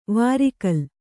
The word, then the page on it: ವಾರಿಕಲ್: vāri kal